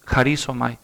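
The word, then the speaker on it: harisomai (χαρίζομαι): Es otro verbo griego que se escribe y suena así, harisomai.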